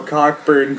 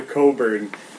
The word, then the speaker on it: Cockburn: but I don't know how Cockburn turns into Coburn.